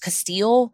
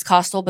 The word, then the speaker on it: Kostial: I did hear it pronounced Kostial, but I really think it's Kostial because this was an episode of 48 Hours and that's how they pronounced it.